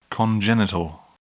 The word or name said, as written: congenital